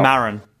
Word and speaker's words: Maron: Maron.